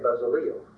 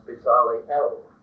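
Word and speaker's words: Bezaleel: And when he gave instructions to Moses to instruct Aholiab, and Bezaliel, you might not like that pronunciation. Most of your Bibles will say Bezaliel, but the Hebrew is more or less Bezaliel, and the El, of course, means God, and Bezaliel means the shadow of God.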